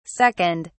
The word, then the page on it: 2nd: British English